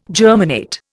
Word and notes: germinate: Indian

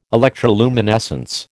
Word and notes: electroluminescence: American English

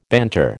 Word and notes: banter: American English

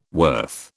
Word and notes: worth: British English

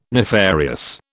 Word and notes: nefarious: British English

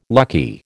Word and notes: lucky: American English

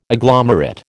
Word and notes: agglomerate: American English

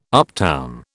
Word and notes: uptown: British English

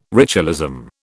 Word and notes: ritualism: British English

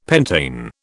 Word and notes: pentane: British English

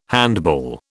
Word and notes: handball: British English

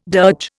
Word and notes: dirge: Indian

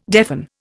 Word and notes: deafen: Indian

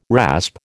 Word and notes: rasp: American English

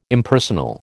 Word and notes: Impersonal: American English

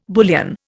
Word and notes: bullion: Indian

